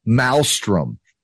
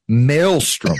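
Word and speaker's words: Malmstrom: For so many of you people that do your own podcast and you don't know how to pronounce this word, it's not Maelstrom. It's Maelstrom, for God's sakes.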